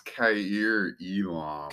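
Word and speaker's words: Kaiir Elam: Okay, with the 26th pick via San Francisco, the Miami Dolphins are going to select K.R. Elam. I just butchered his name. Cornerback out of Florida. – It's pronounced Kair Elam.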